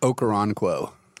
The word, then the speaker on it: Okoronkwo: Okoronkwo.